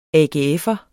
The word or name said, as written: AGF'er